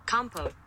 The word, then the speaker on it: compote: Compote. Compote. – Compote. – So it's compost without the S.